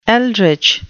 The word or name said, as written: eldritch